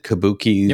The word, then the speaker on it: Kubuqi: Is it Kabuki, yep. Like the theater, yep, in Japan, but it's a different word.